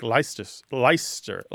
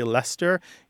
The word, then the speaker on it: Leicester: So a word that's spelled L-E-I-C-E-S-T-E-R did used to be pronounced something more like Leicester. Leicester. – Yeah. – But there were three syllables, and now it tends to be two, okay? Because the two syllables that sound roughly the same, the E-I-C and the E-S-T combine to make one sound.